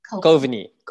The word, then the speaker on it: Kovini: Kovini.